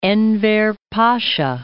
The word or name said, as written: Enver Pasa